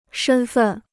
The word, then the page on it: 身份: shēn fèn